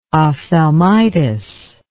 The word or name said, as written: ophthalmitis